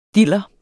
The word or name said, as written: diller